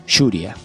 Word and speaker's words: Yuria: Aunque, bueno, la serie en el anime siempre lo pronuncian o pronuncian los japoneses, que es Yuria, así como con Y.